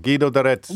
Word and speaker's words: Guido d'Arezzo: Guido D'Arezzo.